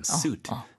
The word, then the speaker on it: suit: shoe는 sh 발음이기 때문에 네. – suit는 suit. – 네. – 양복처럼 suit, suitcase. – 그러니까 숫가마 할 때 그 숫. – 바로 그 숫이죠. – 그러니까 숫 yeah. 약간 강하게.